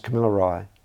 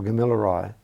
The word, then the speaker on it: Gamilaraay: But it also gets pronounced Camillarai or Gamillarai.